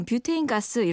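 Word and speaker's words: butane: 부탄가스의 부탄은요, 영어라면 butane라고 써서요. 원어민 발음으로 뷰테인이라고 합니다. – 네. 정확하게 말하면 그럼 뷰테인 가스 이렇게 불러야 맞고요.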